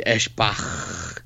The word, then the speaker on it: Eschbach: Guitarist and co-founder Brian Eschbach, I hope I pronounced that right. – Close enough. – Eschbach. – There you go.